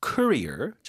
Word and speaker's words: courier: Summon a courier. 자, 뭐 우리가 경력, 커리어 얘기할 땐 career 뒤쪽에 강세인데 이건 앞에 강세예요. Courier, 발음이요? – Courier.